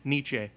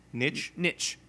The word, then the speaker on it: niche: My niche? Is that right? Niche? – Nietzsche. – Nietzsche? No, that's not right. – Niche? – Niche. Niche. That's it.